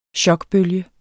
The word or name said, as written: chokbølge